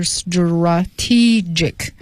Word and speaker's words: strategic: 啊，这个 tr 呢不念成 tra，而念成 dra，浊化的，是 strategic。 – 嗯，在 s 后面就是浊化的 stra 啊 strategic。